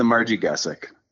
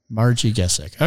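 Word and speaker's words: Marji Gesick: And then the third event, which I can never properly pronounce, even though I think you said it real well before, is? – Yeah, it's the Margigesic. – Margigesic. Okay. Well, it's easy enough when you say it.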